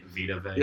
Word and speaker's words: Vita Vea: Vita Vea.